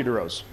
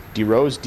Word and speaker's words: DeRose: Sure, Matthew DeRose. – Just spell it and your title. – Sure, Matthew, M-A-T-T-H-E-W, DeRose, D-E, capital R-O-S-E, and I'm the paramedic supervisor.